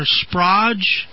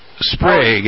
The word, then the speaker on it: Sprague: Eric in the chat room says, I'm not sure how to pronounce that, Sprague or Sprodge? – It's pronounced Sprague, as in, don't be vague, use Sprague.